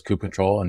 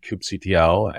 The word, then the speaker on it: kubectl: You mentioned, you know, kube, some people pronounce it as kube control and kubectl. And what else is there? There's TL, kube control.